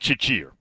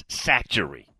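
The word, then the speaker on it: Chachere: Again, it looks like Chichir, but it's not. It's Satchery. You got to know how to pronounce these things, y'all.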